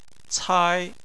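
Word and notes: cai: tone 1

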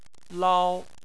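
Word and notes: lao: tone 1

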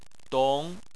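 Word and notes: dong: tone 2

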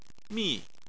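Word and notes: mi: tone 4

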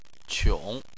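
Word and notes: qiong: tone 3

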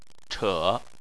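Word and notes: che3: tone 3

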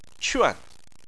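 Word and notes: quan: tone 4

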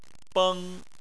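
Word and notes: beng: tone 1

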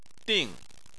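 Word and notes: ding: tone 4

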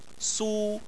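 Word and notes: su: tone 1

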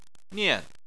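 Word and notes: nian: tone 4

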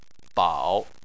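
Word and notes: bao: tone 3

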